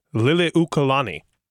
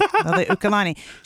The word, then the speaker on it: Liliuokalani: Liliuokalani. – I think that's good. – That's not too bad. – That was pretty good. Liliuokalani.